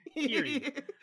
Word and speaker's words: Kiryu: And then the last dude is Kairu. And so you, like, get Kiryu.